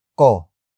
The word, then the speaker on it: ko: ang, trabaho, ko. Doktor, ang, trabaho, ko.